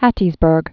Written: Hattiesburg

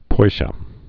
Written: poisha

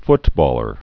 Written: footballer

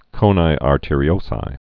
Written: coni arteriosi

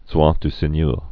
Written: droit du seigneur

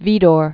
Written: Vidor

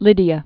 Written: Lydia

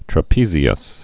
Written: trapezius

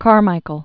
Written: Hoagland Howard Carmichael

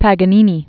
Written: Paganini

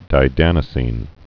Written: didanosine

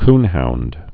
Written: coonhound

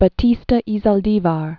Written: Batista y Zaldivar

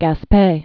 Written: Gaspe Peninsula

